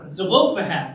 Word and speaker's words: Zelophehad: I've been trying to pronounce that right all week. Zelophehad, that's it.